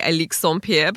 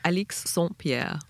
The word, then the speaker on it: Alix Saint-Pierre: Look, if you want to be really French, you could say Alix Saint-Pierre, but we'll just go with Alix Saint-Pierre.